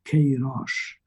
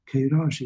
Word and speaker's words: Queiroz: and is Essa de Queiroz. Queiroz is Q-U-E-I-R-O-Z, Queiroz. And he wrote beautiful, brilliant novels. One is called The Maias, M-A-I-A-S, and that's a surname, so it's the name of a family.